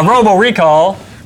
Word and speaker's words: Robo Recall: So, Robot Recall. – Robo. Get the name right, jerk. – Wow. – You're gonna sue us. – Wow. – It's right there on the television. – Ah, Robo Recall.